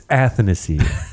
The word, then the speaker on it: Athanasiou: You hate saying his name right. – I love saying his name right. – Athanasiou. No, it's Athanasiou. Look at A-T-H-A-N-A-S-I-O.